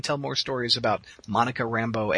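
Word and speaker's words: Rambeau: Rabu? Is that how you say it? – Rambo. – Really? That's Rambo? – Yes, that's how it's pronounced, yes.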